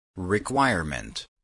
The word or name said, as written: requirement